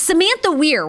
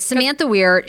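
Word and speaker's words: Samantha Ware: Oh, no, I'm saying it wrong. – I know, no, the Twitter name threw me off, but I think you are saying it correctly. – Okay, it's Samantha Weir.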